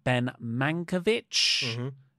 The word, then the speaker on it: Ben Mankiewicz: Ben Mankiewicz is how you say his name.